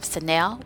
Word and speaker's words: Sanel: So we have Sanel, which is S-A-N-E-L.